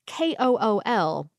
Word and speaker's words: kool: So, K O O L in Dutch is pronounced like coal in English.